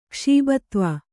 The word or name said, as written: ಕ್ಷೀಬತ್ವ